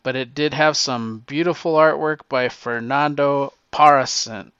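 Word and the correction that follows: Pasarín: Pazarin. That's the best way to pronounce it because I have trouble with a lot of names. But it's Pasarin.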